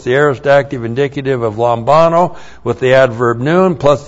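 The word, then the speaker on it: lambano (λαμβάνω): and then the verb lambano. Looks like that in the Greek, L-A-M-B-A-N, long O, lambano.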